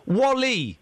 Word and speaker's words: WALL-E: No, Wally. – What, Wall-E? – Well, yeah, but it's pronounced Wally. Why would you call a film Wall-E? That's not like, that doesn't sound like a name. You pronounce Wally.